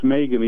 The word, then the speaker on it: M3GAN: It's actually pronounced Megan, even though it's spelled with a three.